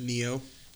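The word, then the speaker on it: Nioh: I think it's pronounced Neo.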